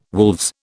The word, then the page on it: wolves: British English